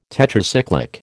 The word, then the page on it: Tetracyclic: American English